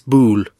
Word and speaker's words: boule: You know the French boule where you throw the balls and hit the jack? – It's boule. – Yeah, boule. Sorry, did I say it wrong? – You said ball.